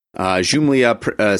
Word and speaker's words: Xumlia: called Lords of Jumlia. Jumlia, spelled with an X, Xumlia.